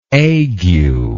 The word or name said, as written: aguelike